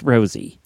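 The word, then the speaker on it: Rossy: It's not Rossi, it's Rosie, she said. Her mom decided to give it it's R-O-S-S-Y, and she said it's pronounced Rosie.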